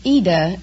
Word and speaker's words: Ida: Well, it's Ida in Italian, but... – Oh, since like Aida? Well, no, there is no A. Oh. just oh. Ida, I-D-A. Oh. But the American pronounce it Ida.